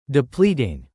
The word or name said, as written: depleting